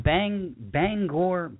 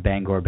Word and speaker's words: Bangor: This is from Bangor, Maine. The third time was the correct pronunciation, incidentally. It's not Bangor. That's just me attempting to pronounce it correctly.